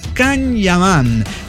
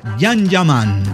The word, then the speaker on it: Can Yaman: Ah, no, perdón, se llama Can Yaman. Se pronuncia. Porque si no, se va a enchilar la vieja. Se pronuncia Jan Yaman.